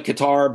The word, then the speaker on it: Qatar: It looks like Qatar, but apparently it's pronounced Qatar, to rehab the knee.